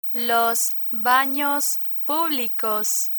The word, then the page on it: los banos publicos: Spanish